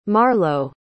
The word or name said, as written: marlow